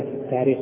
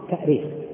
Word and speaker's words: التأريخ: أيهما أصح في الكتابة والنطق في كلمة التاريخ في الهمزة والتأثير؟ طبعا من حيث الأصل الاشتقاقي التأريخ، التأريخ بالهمز، لأنها أرخ يؤرخ، الهمز أصلي فيه، أرخ يؤرخ تأريخا. وأما التاريخ فهو تسهيل،